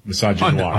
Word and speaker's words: misogynoir: Misogynoir.